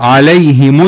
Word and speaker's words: عليهم: قبل الهاء في عندنا ياء، فبقولك إذا جاء قبل الهاء دي ياء ساكنة أو كسرة يكون له في هذه الهاء إيه؟ يكون له في هذه الهاء الكسر، تنطق عليهم الذلة. في قراءات أخرى بتقرأ عليهم الذلة، في الهاء دي بتضم، لا عندنا في رواية حفص الهاء دي بتكسر.